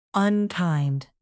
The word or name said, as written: unkind